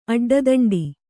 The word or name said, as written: ಅಡ್ಡದಂಡೆ